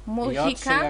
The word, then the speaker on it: Mujica: Nazywa się Jose Mujica. – Mujica.